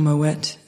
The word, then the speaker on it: Moët: Or Moët is how you're actually supposed to pronounce it.